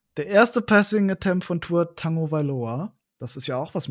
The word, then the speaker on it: Tagovailoa: Man spricht den tatsächlich, das kommt aus dem Hawaiianischen, spricht man das Tagovailoa aus.